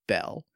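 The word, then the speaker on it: Beall: I would name a Spoink, and I think it's pronounced Beal, but it might be, I know someone with this last name and they pronounce it Bell. It's B-E-A-L-L. I'm gonna say Bell.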